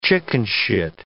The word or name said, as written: chickenshit